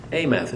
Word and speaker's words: emeth: Ameth. It's spelled E-M-E-T-H. It's pronounced ameth.